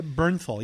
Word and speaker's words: Bernthal: John Barenthal. – Oh, John uh, Bernthal.